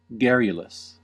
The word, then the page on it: garrulous: American English